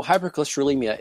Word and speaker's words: hypercholesterolemia: Hypercholesterolemia. Close? – Very well done. Excellent. – What is it, and how does it relate to PAD, peripheral arterial disease? – Okay. So hypercholesterolemia is essentially just a higher level of cholesterol within your bloodstream.